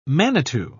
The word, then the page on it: manitou: British English